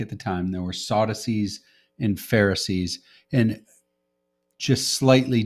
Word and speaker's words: Sadducees: Sadducees.